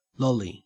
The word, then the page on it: lolly: Australian English